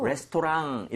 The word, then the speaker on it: restaurant: 그리고 발음할 때 있잖아요. 레스토랑 이렇게 하지 마시고요. R-A-N-T 부분을 살짝 run이죠, run. – 예. – 그냥 비슷하게 해주시면 조금 더 맛이 살아요. – 그래요? – So, restaurant, 이렇게 하지 마시고요.